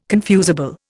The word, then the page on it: confusable: Indian